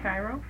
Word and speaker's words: Cairo: C-A-I-R-O. – Cairo? – Are you familiar with that? Do you remember? – Yeah. – Is that how you pronounce Cairo? – Yeah.